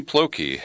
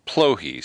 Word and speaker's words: Plokhy: Serhiy Plokhy has authored Chernobyl, The History of a Nuclear Catastrophe, and I just mispronounced his name after practicing because he schooled me that the K is silent. Serhiy Plokhy, spelled P-L-O-K-H-Y.